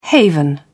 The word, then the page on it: haven: British English